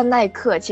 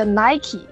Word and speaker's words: Nike: 就是大家这个小科普一下，其实不叫耐克，其实它叫 Nike，英文这个发音，然后法语也叫 Nike，就意思是那个胜利，Victoire 的意思，Victory，是胜利的化身。